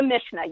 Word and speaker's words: Mishnah: So in the piece in the piece, you also talk about something called Mishnah. Did I say that correctly? – The Mishnah, yes.